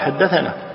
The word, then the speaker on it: حدثنا: لكن باللفظ لابد أن تقال حدثنا ما تقولها باللفظ ثنى هي تكتب ثنى لكن تلفظ حدثنا